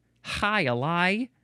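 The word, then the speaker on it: jai alai: I know very little about the game Hi-Ali, but I know that it is spelled J-A-I, second word A-L-A-I, Hi-Ali.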